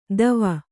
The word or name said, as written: ದವ